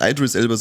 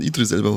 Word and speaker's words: Idris Elba: Und nein, ich habe mich zum Aussprachendepp geoutet beim Suicide Squad, weil der Idris Elba ja nicht Idris Elba, sondern Idris Elba heißt.